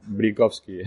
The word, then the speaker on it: Bryszkowski: Brikowski.